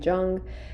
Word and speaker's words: Jung: A lot of people pronounce it differently. Some people say Jung, some people say Jung, but it's Carl Jung from my understanding.